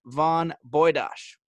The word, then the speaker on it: von Bujdoss: Welcome to the show, Justin Von Boydosh.